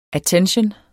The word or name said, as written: attention